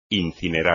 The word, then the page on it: incinerar: espagnol